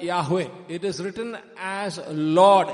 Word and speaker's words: Yahweh: Then we find David using the word Jehovah. It's better pronounced as Yahweh.